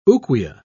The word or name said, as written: ouguiya